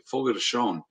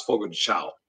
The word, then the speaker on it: Fogo de Chão: Yeah, if you really want a lot of uh, good meat though you got to go and I, and and hobbs told me how to say this correctly fogo de chão i always thought it was fogo de chão that's what I a thought.